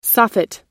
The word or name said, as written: soffit